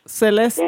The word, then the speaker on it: Celeste: Rio Castel, I think that's how you say it. I'm so terrible at Spanish. – How is it spelled? C-A-S-T-E-L? – C-E-L-E-S-T-E. – Oh. Celeste. Yeah.